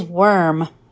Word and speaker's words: worm: The second word is worm. Can you say worm? It rhymes with firm and germ.